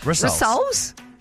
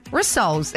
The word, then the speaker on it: rissoles: I felt like you were saying that funny, but that is how you say it. – Rissoles? – Rissoles.